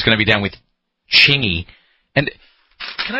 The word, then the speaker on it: Chingy: Chingy. – Chingy.